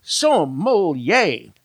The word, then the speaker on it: sommelier: Okay, back with more Grape Encounters right after this, and my very special guest, he is the sommelier. That's how you say it, by the way. Sommelier. Like, yay!